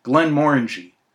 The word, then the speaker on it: Glenmorangie: I went to the Glenmorangie, or is it Glenmorangie, as the Scots would actually pronounce it.